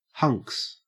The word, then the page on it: hunks: Australian English